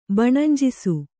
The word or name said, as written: ಬಣಂಜಿಸು